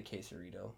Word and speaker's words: quesarito: Quesorito. And they're like, you mean the quesarito?